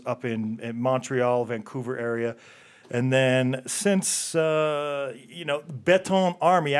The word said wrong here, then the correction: Béton armé: And in case we mispronounced it, here it is. – Béton armé. – Nice. We've been screwing it up the whole time.